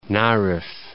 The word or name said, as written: Narev